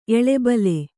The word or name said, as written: ಎಳೆಬಲೆ